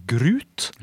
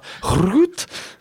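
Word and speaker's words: gruit: Men noen bryggerier i Belgia har jo også eh, brukt noe som jeg trodde um, het grut. Grut! Men det, men det uttales da grut.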